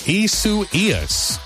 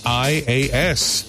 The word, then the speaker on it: Isaias: It's spelled, it looks like Isaias, but it's not. It's pronounced Isu Ias. Isaias.